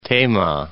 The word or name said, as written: Tema